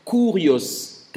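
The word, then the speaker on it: Kurios (κύριος): Quand tu vois Seigneur Dieu, Seigneur Jésus, c'est un mot hébreu que l'on prononce Kurios, K-U-R-I-O-S, Kurios.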